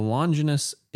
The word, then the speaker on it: Longines: Longines Evidenza. – It's pronounced Longinus. – Oh, yeah. The Longinus Evidenza with a sector dial.